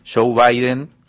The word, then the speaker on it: Joe Biden: Ahora Alberto Fernández y su ministro de Economía Martín Guzmán esperan con ansiedad la asunción de Joe Biden que tendrá lugar el próximo 20 de enero.